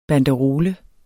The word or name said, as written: banderole